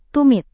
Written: tumit

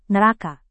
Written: neraka

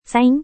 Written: saing

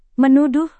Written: menuduh

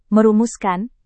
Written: merumuskan

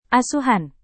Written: asuhan

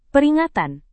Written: peringatan

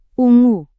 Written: ungu

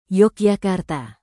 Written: Yogyakarta

